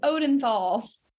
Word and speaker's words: Odenthal: My new last name, my married name now is Odenthal.